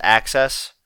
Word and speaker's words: AXS: It's probably supposed to be pronounced Access, but it's spelled AXS.